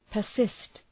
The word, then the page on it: persist: pər’sıst